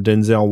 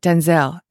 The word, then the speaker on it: Denzel: By the way, it's not Denzel. It's how we pronounce it, but he actually clarified it's Denzel.